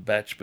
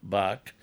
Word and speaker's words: Bach: People say we should pronounce his name Batch, but I've always pronounced it Bark.